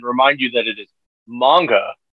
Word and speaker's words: manga: It's manga.